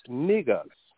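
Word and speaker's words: Negus: The word is, I'm going to spell it, N-E-G-U-S. N-E-G-U-S. It's niggas.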